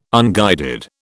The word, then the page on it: unguided: British English